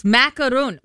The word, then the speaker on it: macaron: Macaron. Macaron. The French pastry. The French pastry. – Why is it all of a sudden three syllables?